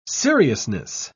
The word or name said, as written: seriousness